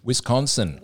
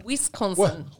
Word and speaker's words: Wisconsin: states and cities and – Wisconsin. – Wisconsin.